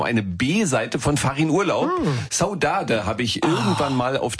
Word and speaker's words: Saudade: Ja, aber Saudade hast du so gut ausgesprochen, das ist nämlich Portugiesisch.